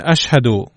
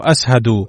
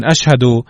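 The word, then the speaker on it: أشهد: اسهد بدلا من اشهد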